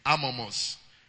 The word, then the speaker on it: Amomos: Amomos. A-M-O-M-O-S.